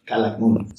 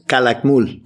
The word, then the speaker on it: Calakmul: There's something called the volcano of, and I'm going to pronounce this wrong, the volcano of Calakmul. Say it again. – Calakmul.